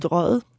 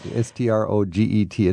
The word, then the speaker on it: Strøget: Strøget, yeah, which is completely difficult to pronounce. – Say it again. – Strøget. – Strøget. It's like strolling almost.